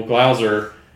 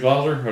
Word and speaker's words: Glauser: Like I was saying, I know you just had one with Daniel Glauser. Am I pronouncing that right? – Yeah. – Is it Glauser? Okay.